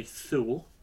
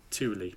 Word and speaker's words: Thule: I see it written down, I want to say Thule. – Okay. – But it's Thule, pronounced okay. Thule.